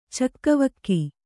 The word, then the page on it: ಚಕ್ಕವಕ್ಕಿ: cakkavakki